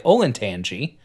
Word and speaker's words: Olentangy: I know that the Olentangy River, which is spelled Ol, O-L-E, N, the letter N, Tangy, T-A-N-G-Y, Olentangy.